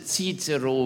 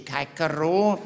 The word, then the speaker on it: Cicero: We correctly pronounce Cicero. We do not say Caicero.